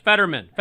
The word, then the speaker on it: Fetterman: You have Oz's race and was it Fetterman? Is that how you say his name, Fetterman? – Yes, Fetterman. Fetterman, yep.